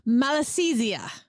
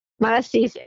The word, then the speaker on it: Malassezia: And I've been involved in horses for a long time, and you taught me how to pronounce it, and it's called Malassezia. – Malassezia, yes.